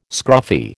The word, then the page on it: scruffy: American English